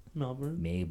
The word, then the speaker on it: Melbourne: You know Melbourne's one syllable. – Okay. – You have to say it like that. – SF. Melbourne. – Yeah. – SF. – Melbourne. You can't say it with two.